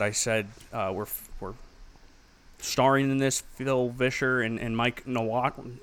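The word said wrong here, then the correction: Nawrocki: I can't even say his name. Mike Nowrocki. Nowrocki.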